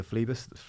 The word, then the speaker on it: Phlebas: Something that someone mentioned actually was that particular piece of poetry that lends its name to Consider Phlebas, the is that how you say it? I hope it is, yeah.